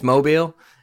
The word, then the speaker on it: Mobile: I think it's Mobile.